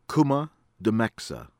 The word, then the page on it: AHMED, ABIY: AH-bee AKH-mehd